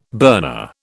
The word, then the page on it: burner: British English